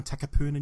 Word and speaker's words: Takapuna: Retro Space Sci-Fi Collectibles in downtown Takapuna, New Zealand.